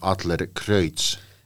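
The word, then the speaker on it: Adlercreutz: onko siellä sitten jotain muita jokerikortteja Adlercreutzin? – Mun täytyy nyt, mun täytyy nyt äh, ensinnäkin oikasta sinua siinä, että siis sehän lausutaan Adler Kreutz.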